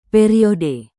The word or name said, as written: periode